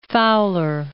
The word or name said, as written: Fowler